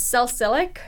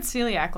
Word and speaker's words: salicylic: I always pronounce it wrong. It's salicylic. Salicylic. – Salicylic, yeah. Yeah. We said celiac last time, which is...